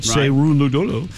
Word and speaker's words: Cerúndolo: He loses to Juan Manuel Cerúndolo. I hope I'm saying that right. Cerúndolo.